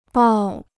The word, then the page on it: 抱: bào